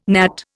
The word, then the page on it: gnat: Indian